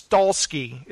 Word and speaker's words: Stahelski: Oh, Stahelski. – I think it's Stahelski is the way it's pronounced.